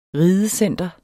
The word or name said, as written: ridecenter